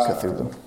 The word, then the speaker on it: Cthulhu: It's just Cthulhu.